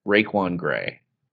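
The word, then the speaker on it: Raiquan Gray: This guy's name is Raekwon Gray, and it is not spelled like the rapper. It is R-A-I, capital Q-U-A-N, and then G-R-A-Y. Raekwon Gray.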